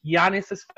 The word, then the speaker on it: Giannis: I think there's a strong smell and rumor out there that like he's very tight with Giannis's family. Like I got like called out on Twitter for mispronouncing his name, so I'm gonna pronounce it correctly. It's Greek. It's Giannis, like Giannis.